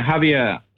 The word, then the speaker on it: Javier: Is that how you pronounce his name? – Yeah, Javier. He's Spanish.